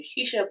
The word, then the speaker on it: Sheesha: And the people keep saying that people have said my name wrong my entire life. That's why I do Shisha, but it's still a lot of a mouthful.